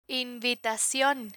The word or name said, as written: invitacion